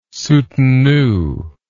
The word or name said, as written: soutenu